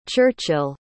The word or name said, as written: churchill